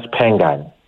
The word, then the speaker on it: Spengane: Yamkela Spengane.